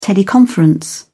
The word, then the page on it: teleconference: British English